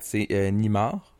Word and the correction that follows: Neymar: Neymar, je sais pas si je le prononce bien. Oui, Neymar.